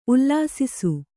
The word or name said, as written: ಉಲ್ಲಸಿಸು